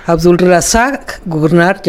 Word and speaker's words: Abdulrazak Gurnah: Más Abdulrazak Gurnah,